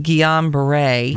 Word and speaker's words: Guillain-Barré: Now, I don't know how people pronounce it. We looked it up online. – Mm-hmm. – They pronounced it Guillain-Barré right.